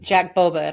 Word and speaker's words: Jack Boba: Well, it's like you did a podcast with Jack Boba, and am I saying that correctly? He, Yep.